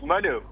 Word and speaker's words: Manu: You're saying my name wrong, too. It's Manu. – Manu? – Yeah, Kelly's spelling. – Kelly's spelling. Manu, I can only apologise. – That's all right. It's like the football team.